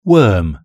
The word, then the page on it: worm: British English